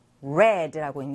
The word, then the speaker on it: read: 자, read의 과거형 똑같이 쓰였지만 어떻게 읽어야 되죠? read라고 읽는다고 했어요. read, read.